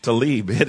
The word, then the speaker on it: Tlaib: Help me with the pronunciation. Rashida Tlaib? Tlaib? Thank you. Tlaib, and he's right.